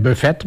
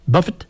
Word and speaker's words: Buffett: Zo moet je dat uitspreken. Het is geen Buffett, maar het is Buffett. Zeggen ze in Amerika, geloof ik, zoiets.